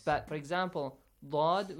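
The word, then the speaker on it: Dod (ض): But Dod, even if it has kasra, again, it has that heavy, like sound, it is heavily pronounced.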